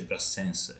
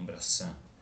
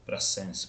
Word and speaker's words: Brassens: la S alla fine dei cognomi non si dice, ma nel francese del sud si dice Brassens e non Brassin, Brassens, perché la S in realtà è un cognome occitano,